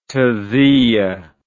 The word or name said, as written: taziyah